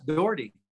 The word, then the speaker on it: Doughtery: Is that how you say your last name? – You said it just right. Some in Scotland, it's Dr. Doherty. – Oh, cool. – But yes, Doughtery.